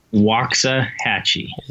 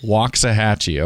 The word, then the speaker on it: Waxahachie: I'm actually in my flagship store at home in Waxahachie, Texas today. – I got to say that right. Waxahachie, Texas? Did I get it even close? – Waxahachie. – Waxahachie. Okay.